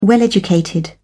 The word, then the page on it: well educated: British English